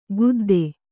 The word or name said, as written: ಗುದ್ದೆ